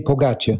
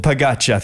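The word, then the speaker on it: Pogačar: It's Tadej Pogacar. – Pogacar, thanks, mate.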